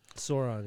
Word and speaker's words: Sauron: Sauron, yeah.